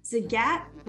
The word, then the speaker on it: Zagat: Zagat rhymes with the cat in the hat.